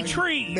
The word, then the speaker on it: Chree: T-R-E. – Oak tree? – No, no. However, it's spelled C-H-R-E-E, but it's pronounced tree, as in ch, with the C-H, like ch, cheese, but ch, and then re, so tree.